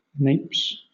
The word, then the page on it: napes: British English